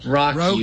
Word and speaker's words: Roku: Roku, if you can't pronounce a joke. – I'm looking at the Roku forums, and they're saying it's Roku.